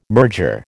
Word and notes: merger: American English

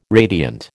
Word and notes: radiant: American English

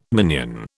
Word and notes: minion: British English

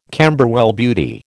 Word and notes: Camberwell Beauty: American English

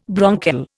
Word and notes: bronchial: Indian

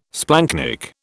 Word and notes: splanchnic: British English

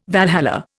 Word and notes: Valhalla: Indian

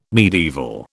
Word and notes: medieval: British English

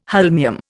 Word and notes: holmium: Indian

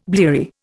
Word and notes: bleary: Indian

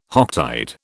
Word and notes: Hocktide: British English

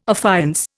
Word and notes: affiance: Indian

